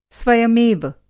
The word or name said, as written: स्वयमेव